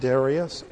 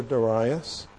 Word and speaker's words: Darius: That brings us to D, King Darius, a new king now over the Persian Empire, can be pronounced Darius or Darius.